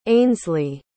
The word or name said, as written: ainslie